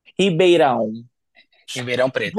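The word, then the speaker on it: Ribeirão Preto: Next up is the 22 year old from I'm going to say this wrong, and you are going to tell me how to say it properly. Ribeira Preto. Ribeirão. Preto.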